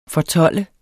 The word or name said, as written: fortolde